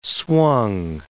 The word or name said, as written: swung